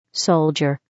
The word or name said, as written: soldier